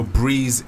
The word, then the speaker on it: Breis: So Breeze is spelled B-R-E-I-S.